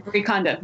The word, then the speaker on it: Marie Kondo: You mentioned her book in your book, Marie Kondo. Am I getting the name right? – Yeah, Marie Kondo.